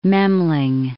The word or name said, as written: Memling